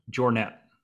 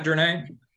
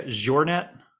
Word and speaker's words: Jornet: It's Kilian Jornet. – Is it Jornet, not Dornay? – It's not. It's actually more like a Jornet.